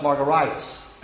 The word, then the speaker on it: Margaritis: But if you look at the spelling of my last name, it ends in an IS, not an AS. And it actually should be pronounced Margaritis, like hepatitis, gingivitis, laryngitis. My name's not fine.